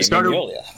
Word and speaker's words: Mignola: Mignola.